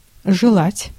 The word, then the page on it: желать: /ʐɨˈlatʲ/